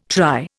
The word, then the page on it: try: Indian